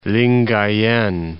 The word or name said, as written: Lingayen Gulf